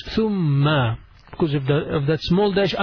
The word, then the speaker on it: ثم: ثم اتخذتم العجلة ثم then after that ثم then it's not ثم it's ثم you pronounce it a little bit taking your tongue out ث ث ثم ثم means then ثاء ميم and a تشديد on top of the ميم so we say ثم ثم